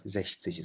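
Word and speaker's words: sechzig: ونفس الشيء هون كمان الزكس، قلنا هون تلفظ كحرف إكس التسيها، ولما بتصير 60 كمان مثل 16 هنيك قلنا زش تسين، صارت حرف شين. هون نفس الشيء كمان بتصير حرف شين، زش تسيش.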